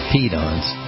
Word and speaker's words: Peedons: Yeah, I spell it, I pronounce it Pedons, P-E-E-D-O-N-S, Pedons.